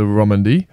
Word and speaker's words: Romandie: At the moment, it's Tour de Romandie.